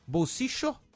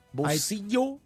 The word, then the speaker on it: bolsillo: O caçapo em espanhol, que se pronuncia bolsio, que tem as três pronúncias do duplo L lá na Argentina. – Isso. – Né? Bolsicho, como se fosse um CH, né? – Parece que ia ser mais pro norte, né, do país. – Exatamente. – Né? – Bolsicho. – Bolsillo. – Tem um bolsillo. – Ou bolsillo. – Bolsillo. – Né? E tem um bolsillo. – Bolsillo. Que é da região central do país, região de Córdoba, né? Em Buenos Aires se fala mais bolsillo.